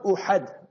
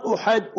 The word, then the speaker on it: Uhud: Not Uhud. Learn to pronounce the words properly. Not Gaza, Gaza. Not Uhud, Uhud.